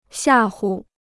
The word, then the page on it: 吓唬: xià hu